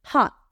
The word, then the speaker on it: hot: Pongan la boca en forma de A, pero digan O. Oh, hot. Hot. Si entienden lo que quiero decir, así les va a quedar la pronunciación mucho más bonita. En lugar de hot, porque no es hot, ni tampoco es hat, es hot, hot. Boca en forma de A, dices O.